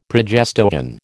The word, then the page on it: progestogen: American English